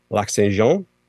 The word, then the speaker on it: Lac Saint-Jean: And so I was told that if I wanted to see a very authentic tortillere in Quebec, I should go to Lac Saint-Jean, if I'm saying that correctly. – You are saying that correctly, yep. – They have a tortillere Lac Saint-Jean.